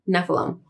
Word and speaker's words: Nephilim: The biblical lore, Nephilim. Am I saying that right? – Nephilim.